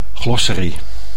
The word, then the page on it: glossary: Dutch